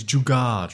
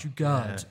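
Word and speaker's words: jugaad: And there's a word that I came across in your research, and you're going to have to forgive me if I mispronounce this, but jugad? – It's jugad. – Jugad.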